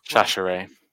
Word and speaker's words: Chachere: And our old friend, Andre Chachar, who I've definitely pronounced Chachere. – Chachere. They've also got Kaiser White, a linebacker.